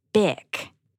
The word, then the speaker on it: Bic: In the States, we pronounce his company Bic.